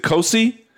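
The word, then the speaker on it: Cosce: Help me if I'm pronouncing the last name correctly. Is it Kosi? Orion Kosi? – Yes.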